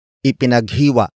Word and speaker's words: ipinaghiwa: Ipinaghiwa ni Jessica si Peter ng keso.